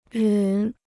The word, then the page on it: 忍: rěn